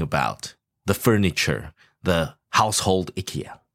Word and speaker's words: IKEA: And the correct pronunciation from the founder is IKEA. – IKEA. – Yeah. – Oh, it's IKEA. Should be pronounced in the Swedish way.